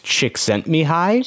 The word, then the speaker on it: Csikszentmihalyi: So I believe that name is actually pronounced Csikszentmihalyi. – Csikszentmihalyi. Csikszentmihalyi. That's a cool name. – Yeah, it's a Hungarian name.